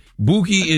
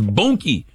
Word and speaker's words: Boonkie: No, it don't rhyme with Dookie. Boonkie, and it'd be Boonkie if it was Dookie. It's Boonkie. – Oh, right. – With an N. Boonkie.